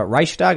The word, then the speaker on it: Reichstag: And the funny thing is they're marching next to the Reichstag, is that how you pronounce it? – Mm-hmm. Yeah.